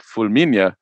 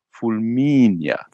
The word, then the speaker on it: Fulminea: And I had to learn to pronounce it because I always pronounced it Fulminea. And I said, no. Alec, you said authentic Italian, right? Like it's Fulminea.